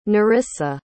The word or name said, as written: Nerissa